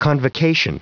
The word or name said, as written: convocation